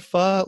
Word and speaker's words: pho: P-H-O. That's how you pronounce it. It's pho, people.